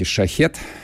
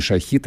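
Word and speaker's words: Шахед: слегка перемаркированные беспилотники «Шахет», не шахит, а шахет по-персидски это произносится.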